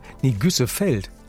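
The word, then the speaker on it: Güssefelde: Um Wintergüßfeld, ich hoffe, ich habe es richtig ausgesprochen. Ja, bestimmt. – Da haben wir aktuell, Güssefeld.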